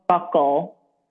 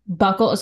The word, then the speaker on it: buccal: I don't know if it's pronounced buccal or buckle. – Oh, buckle. – Okay. Buckle.